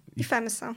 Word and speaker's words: IFMSA: Ten skrót, ta instytucja, o której powiedziałaś, dla mnie był dziwny, bo jest IFMSA, ale mówisz, że się wymawia IFEMSA. – IFEMSA, okej, okay, no to trochę łatwiejsze.